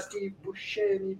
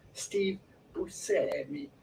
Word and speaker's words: Buscemi: It's Steve himself says that he does not pronounce his own name this way, but he pronounces it rather... Steve Buscemi.